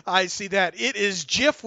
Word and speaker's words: GIF: And it's pronounced GIF. We finally got that. The guy who invented that took care of that.